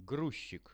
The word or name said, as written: gruzchik